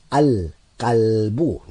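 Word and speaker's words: al-qalbu (الكلب): I will say al-qalbu. Notice the lam. Al, the lam is being pronounced. Al-qalbu is being pronounced with a sakin on it. Al-qalbu, the dog.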